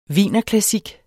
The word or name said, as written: wienerklassik